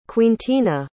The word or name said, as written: quintina